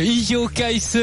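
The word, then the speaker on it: Iljo Keisse: Je ne sais pas pourquoi Jérôme se marre, ça doit être ma prononciation de Ijo Kaïs, le coureur belge de l'équipe Etix Quickstep, qui était un équipier. – Comment dire C'est parfait comme ça.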